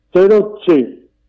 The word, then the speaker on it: Terucci: Porque la C se pronuncia como Che. – Claro. – Y la doble Z, que es para tal, es Terucci.